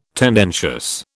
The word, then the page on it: tendentious: British English